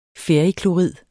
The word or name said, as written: ferriklorid